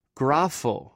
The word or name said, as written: grafw